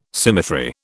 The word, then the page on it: symmetry: British English